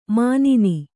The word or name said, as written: ಮುನ್ನಿ